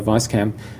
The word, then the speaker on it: Weisscam: the Phantom and the Weisscam. I say Weisscam